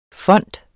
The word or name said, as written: FONDETS